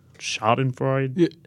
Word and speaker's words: Schadenfreude: I mean, the other question I'm curious about is, like, is Indiana, like, is there a little bit of how do you pronounce that word? Schadenfreude? – Yeah, that's right. – Is that how you pronounce it? – Yep.